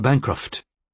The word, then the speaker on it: Bancroft: Sarah Bancroft, she added, stressing the first syllable of the rather patrician-sounding family name.